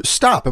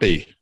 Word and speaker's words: Stoppe: Um, Arthur, you want to want to start with you, Arthur? Stop. Am I pronouncing your last? – Stop. Stoppy. Stoppy. Stoppy.